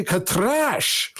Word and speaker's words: Katrash: And Ramud says, you have to say Katrash. And then Eric's like, Katrash, Katrash. He goes, no, Master Eric, roll your R's.